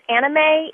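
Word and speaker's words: Aana-Mei: My nickname is my proper nickname is Anna Mei. It's spelled A-A-N-A and then hyphen M-E-I.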